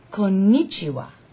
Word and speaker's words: Konnichiwa: Konnichiwa, please repeat. – Konnichiwa. – Perfect. Good. You say Japanese word like you're Japanese.